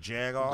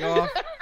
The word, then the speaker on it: jagoff: Fucking Jagoff. – Right. – Jagoff. – It's a hard J and a hard G, man.